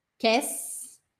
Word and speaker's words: Qu'est-ce: Quer, quer, quess, quess, quess, quess. É como se fosse quis, mas com é.